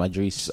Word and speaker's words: Idris: It's spelled like Idris Alba, right? – Yeah. – But you say Idris. – He's the right way.